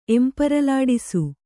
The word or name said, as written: ಎಂಪರಲಾಡಿಸು